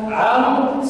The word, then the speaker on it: عمرو: ويقال من اللطائف ان عمرو سرق واو داود، عمرو سرق واو داود، فلا بد الفرق بواوين وتكتب وان واحده واحده. واما عمرو فتكتب ولا تلفظ، وكتبت من باب التفريق بين عمر وعمرو.